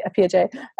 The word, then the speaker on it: Appiagyei: Actually, you said my name the right way. I said it the like British way that we sometimes say it. It's actually Appiagyei.